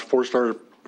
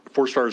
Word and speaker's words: Fourstar: For the record, my name is Lance Forstar. Forstar is spelled F-O-U-R-S-T-A-R.